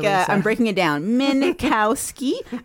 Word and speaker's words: Minkowski: So furthermore, Ido Minkowski, how would you even pronounce that? Minkowski. This is the spokesperson for Black Cube.